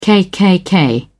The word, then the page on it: KKK: British English